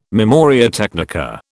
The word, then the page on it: memoria technica: British English